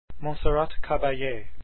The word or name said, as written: caballe